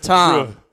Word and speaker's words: Tom: Last week, Tom, you pronounced his name Thom. – I did. – He hit me up on Twitter. He was not pleased with that. He said, come on, bro. It's Tom.